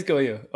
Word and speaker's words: Gojo: And it is Gojo. – It is Goyo.